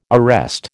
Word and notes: arrest: American English

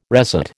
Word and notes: resat: American English

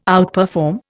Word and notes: outperform: Indian

